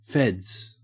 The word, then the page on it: feds: Australian English